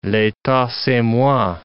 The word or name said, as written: l'etat, c'est moi